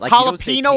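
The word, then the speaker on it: jalapeño: Jalapeno is like N-Y-O, nyo.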